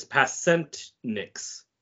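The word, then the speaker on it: Pasečņiks: I know how to pronounce his last name. It's Pasenix.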